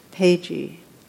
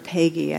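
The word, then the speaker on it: Péguy: It's from The Portal of the Mystery of Hope by Catholic theologian and poet Charles Pagey, or Pagey, I think is the way it's pronounced.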